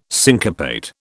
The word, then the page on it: syncopate: British English